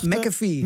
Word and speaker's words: McAfee: Hoe moest ik het nou uitspreken? Waar lag McAfee. – McAfee is overleden.